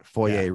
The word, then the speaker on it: foyer: How do you say F-O-Y-E-R? – I say foyer, but yeah. – Foyer rhymes with lawyer, not foyer rhymes with boy A.